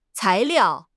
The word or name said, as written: cai2 liao4